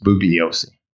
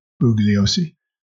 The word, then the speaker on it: Bugliosi: I mispronounced it at first, and I sent you the wrong direction. It's Bugliosi. – Bugliosi.